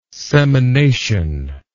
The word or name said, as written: semination